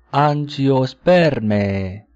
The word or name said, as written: angiospermae